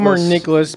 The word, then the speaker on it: Nicklaus: Palmer Nicholas, spelled like Jack Nicholas.